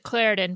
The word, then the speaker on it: Claritin: We pronounce it Claritin, but yes.